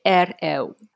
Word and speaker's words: Hereu: 然后比如说我买的那两双鞋，其实是一个西班牙的品牌，叫 Herreu，我我的发音应该不正确，呃，H E R E U，F。来帮我一下。 – 这个发音本身也比较困难，用西语发的话是 air l。